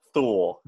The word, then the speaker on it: Thule: And it looks like it rhymes with mule, Thule.